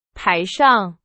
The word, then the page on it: 台上: Chinese